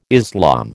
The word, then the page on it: Islam: American English